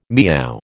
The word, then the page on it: meow: American English